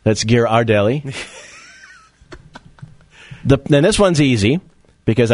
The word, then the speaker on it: Ghirardelli: Is me saying Ghirardelli correct? – Is it Ghirardelli or Ghirardelli? Yeah, I can't even pronounce it myself. Ghirardelli's, I thought. – Neither one. – Okay, which one? It is actually Ghirardelli.